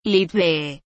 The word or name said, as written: Lttbeh